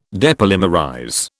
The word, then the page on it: depolymerize: British English